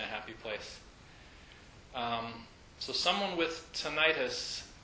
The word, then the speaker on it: tinnitus: So out of the blue, I had become one of apparently 20 million people in the United States who suffer from a condition called tinnitus, also pronounced tinnitus apparently. But I think people are more familiar with tinnitus as the pronunciation.